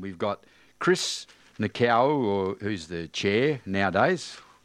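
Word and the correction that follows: Nikou: How do you pronounce his name? Nicau, is it? – Nicu.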